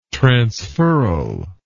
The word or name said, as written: transferral